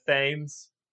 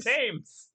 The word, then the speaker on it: Thames: I'm assuming it's by the Thames. – By the Thames. Ooh, nice. It's a classic Roy and Keeley. – Nice. Thames.